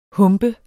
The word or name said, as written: HUMPENDE